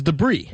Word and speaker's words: debris: It's, so it's debris plural. You don't pronounce the S.